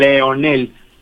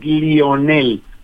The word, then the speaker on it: Lionel: Entonces por eso su mamá no le importó, porque muchos dicen Leonel, pero es Lionel Andrés.